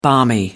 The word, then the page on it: balmy: American English